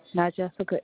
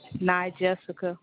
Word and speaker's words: Nyjessica: Not Jessica Edwards. – Nashika, can you spell that for me? – Not Jessica. – N-A-Y? – N-Y-J-E-S-S-I-C-A.